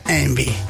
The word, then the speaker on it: Yngwie: No, Yngwie. – No, si lo buscas escrito es imposible. Y-N-G-W-I-E. – Ah, imposible. – Por eso. Pues se pronuncia Envy.